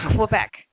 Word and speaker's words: Quebec: And it's Quebec. Not like you guys, it's like, you make the K like Quebec.